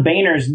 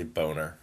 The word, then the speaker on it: Boehner: John Boehner's new book. It's a barn burner. Everyone's going to love it. – I think you pronounced his name wrong, though. It's definitely Boehner.